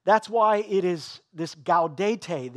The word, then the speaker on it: Gaudete: I would have pronounced it Gaudete, but it's G-A-U-D-E-T-E, something like that.